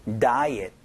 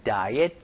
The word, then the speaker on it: diet: 일단 다이어트라는 발음보다는요, diet 이렇게 발음되니까 이것 좀 주의해 주시면서 연습 한번 해보도록 하죠. 갈게요. 시작. How is your diet going?